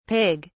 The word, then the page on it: pig(s): British English